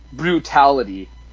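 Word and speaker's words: Brewtality: Brutality. It's spelled B-R-E-W. Mm-hmm. Tality.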